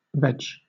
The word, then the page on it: veg: British English